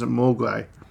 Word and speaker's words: Mogwai: In Cantonese, Mogwai means devil, demon, or gremlin. The Mandarin pronunciation is Mogwai.